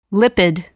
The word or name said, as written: lipid